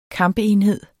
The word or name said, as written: kampenhed